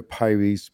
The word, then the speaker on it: Pires: My name is Alexander Pires, P-I-R-E-S.